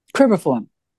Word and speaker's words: cribriform: how do you say that, cribriform? – Cribriform. – Cribriform plate.